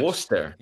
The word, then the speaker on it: Worcester: Is that how you say it's it? Pronounced, Worcester. You have one of those in, in, in the States as well. I think in Massachusetts, Worcester. It's pronounced Worcester, Massachusetts, where Worcester, with the original Worcester.